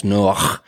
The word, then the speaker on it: Noach: Yeah, so the name Noah, it's in the Hebrew, it's actually pronounced Noach, and it means in Hebrew rest.